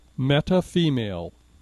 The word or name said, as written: metafemale